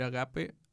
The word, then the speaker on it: ágape: Es un amor que se expresa con devoción, por ejemplo, el sentir agape hacia la naturaleza, la divinidad, la humanidad, entre otras cosas. – Entonces, por ejemplo, podríamos sentir... También otra cosa, tiene acento en la A, entonces no sé si es agape o agape.